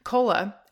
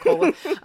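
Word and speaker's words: Kolah: Cola, just because it's spelled K-O-L-A-H. A lot of people are like, you've never heard of Cola.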